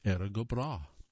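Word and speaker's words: Erin go Bragh: How do you pronounce B-R-A-G-H? The English pronunciation of Éireann go is exactly the way it's spelled. The Og, A-G-H, in Breá makes an H sound. Put together, it's pronounced Éireann go Breá.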